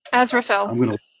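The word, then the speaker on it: Aziraphale: And is it as, how do you pronounce that? – Azraphel. – Azraphel. Okay, thank you.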